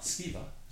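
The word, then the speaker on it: Sceva: Siva, is that right? Mm-hmm. Did I say that right? – Dobrze powiedziałem? – Yeah.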